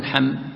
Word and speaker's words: حم: لأنك تنطقها أنت مقطعة، ما تقول حاميم تقول حم، مثل ما تتكلم بكلمات أخرى تقول نعم، لا، ما هذه تنطق بحرف حرف حرف حرف.